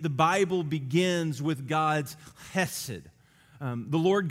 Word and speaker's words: chesed: But this word is chesed. And it's spelled C-H-E-S-E-D. Chesed.